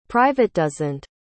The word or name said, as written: privatdozent